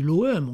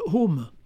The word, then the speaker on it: OM: Enfin, bon, on dit l'OM, on dit pas home, d'accord, donc. – On dit quoi ? – On dit l'OM, on dit pas home.